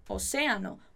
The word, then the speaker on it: océano: Oceano. Muito cuidado com essa pronúncia da palavrinha oceano, que é oceano. Por isso que a gente tem esse acento ali na palavra para dar ênfase dela.